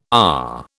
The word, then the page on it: AR: British English